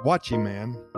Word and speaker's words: guachiman: The guachiman, pronounced watchyman, is exactly what it sounds like a watchy man.